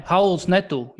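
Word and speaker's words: Raul Neto: Vēl ļoti nesaistīta lieta ar to, kas notiek laukumā Wizards komandā, bet Hauls Neto, ja gribam būt laikam precīzi. Nē, Hauls Neto, jo Brazīļiem O kā U izrunājas. Zinu, ko viņš man atgādina, es vienkārši nesen nostījos vēlreiz filmu Neighbors, bija tāda filma ar Seth Rogen.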